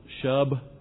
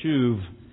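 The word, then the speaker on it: shuv (שוב): I know it looks like shub, but it's pronounced shuv, and it's the consistent Hebrew word in the Old Testament, which means to turn or to turn back.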